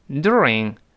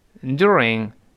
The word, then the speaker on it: during: 嗯，但在美国呢，这个 you，呃，老外这老美觉得费劲，他往往把那个 e 去掉，只剩一个 o o，所以美国人是读成 during during，它是发 o o，再发 d d 是 during 就没有 z 这个发音变化了，那个 e 没有了，那叫 during during during。